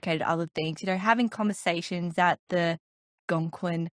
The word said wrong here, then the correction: Algonquin: Hotel. Did I say that right? – Algonquin, yeah.